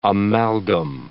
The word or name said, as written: amalgam